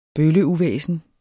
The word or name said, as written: bølleuvæsen